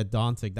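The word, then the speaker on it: Dončić: And it was Doncic, Luka Doncic. That's how you pronounce it.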